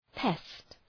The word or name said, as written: pest